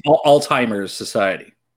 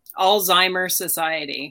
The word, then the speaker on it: Alzheimer Society: So what's the proper pronunciation? – Alzheimer Society. – Alzheimer's. – Yes. – I knew Society. – It. – Perfect. Alzheimer Society. Oh, no S.